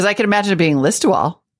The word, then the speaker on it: Listowel: And he writes, I wanted to correct the pronunciation of the town, which sure looks like Liz Towel, but is pronounced Listowel? – Listowel.